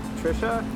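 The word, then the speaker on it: Tricia: So, Trisha? – Yes.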